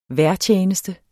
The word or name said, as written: vejrtjeneste